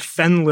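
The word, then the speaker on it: Fenne: The thing that is making me happy this week is a fantastic new album by a singer-songwriter from the UK named Fen Lily. That is spelled F-E-N-N-E. I had been mispronouncing it as Fene, like the pasta.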